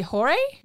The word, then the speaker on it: Jorge: but it's actually Jorge. Yeah. And so I had to I had to write it down because I'm like, you're gonna say George like a fucking asshole. That's not how it's pronounced.